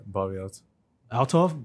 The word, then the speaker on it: Althoff: I don't know how to say her last name. Althoff? – Yeah, Bobbi Althoff.